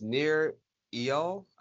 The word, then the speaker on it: Nir Eyal: Well, yeah, it's not too bad, but it's his name is Nir Eyal. I think he pronounces it that way, but it's Nireyal. His last name is Eyal, and it's called Indistractable: How to Control Your Attention and Choose Your Life.